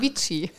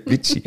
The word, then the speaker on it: Vicci: Doch, Vici. Vici. V-I-C-C-I packen wir in die Shownotes.